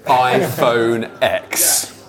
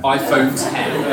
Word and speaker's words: iPhone X: iPhone X. The best phone in the world. – It's pronounced 10. It's 10. – iPhone 10.